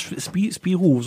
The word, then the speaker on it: Spirou: Spiru, so Spiru, wie spricht man denn aus? Spirou, so französisch.